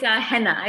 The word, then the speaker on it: Haina: Is it Haina or Haina? – It's Haina. It's my Korean name. – Henna, and you say it almost like henna tattoos, yeah.